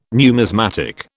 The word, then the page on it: numismatic: British English